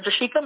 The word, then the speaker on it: Jershika: Um, so my social media Facebook page is J- Jershika Maple.